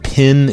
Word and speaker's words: pen: You can pronounce it pin if you want, and that it's common for people from the South or people, I forget actually, but they said people from the South, they pronounce it pin instead of pen.